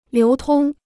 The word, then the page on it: 流通: liú tōng